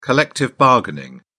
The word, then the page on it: collective bargaining: British English